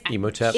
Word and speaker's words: Imhotep: Well, so he doesn't sound like such a bad guy, but he's totally the Imhotep. Am I saying that right? – Imhotep.